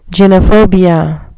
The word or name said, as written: gynophobia